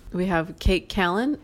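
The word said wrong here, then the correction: Kato Kaelin: Is that how you say your name? – It's Kato Callan.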